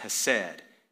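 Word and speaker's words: hesed (חסד): I think better pronounced hesed. Hesed.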